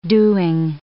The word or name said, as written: doing